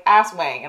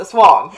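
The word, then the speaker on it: Aswang: ass wang, and I was like... And the guy's like, it's not how you say it, it's a swang.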